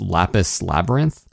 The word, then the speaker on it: Lapis x Labyrinth: And they specifically say on the website, you don't pronounce the X. It's just Lapis Labyrinth.